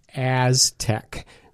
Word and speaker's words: ASTC: Yeah, the acronym is AZTEC. What's kind of hard to pronounce because there's no vowels in there. So AZTEC is ASTC, Association of Science and Technology Centers, AZTEC.